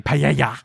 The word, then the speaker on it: paella: Paella. – Oh. – Paella. – Paella. – Sounds like Antonio Banderas. Paella. Paella. – Paella. – So you got to get that paella. Soft J in there.